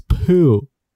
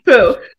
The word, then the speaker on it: Pugh: Florence Pugh. – That's how you say it. That's how you say it, right? – Yeah. – Pug. – Pugh. – Florence Pugh.